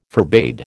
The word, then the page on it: forbade: American English